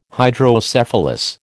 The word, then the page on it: Hydrocephalus: American English